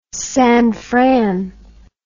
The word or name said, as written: San Fran